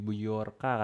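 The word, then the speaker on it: Bjorka: Ya, hacker Bejorka ya, Bejorka katanya bacanya.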